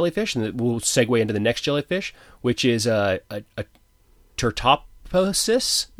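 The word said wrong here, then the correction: Turritopsis dohrnii: Turritopsis dorni.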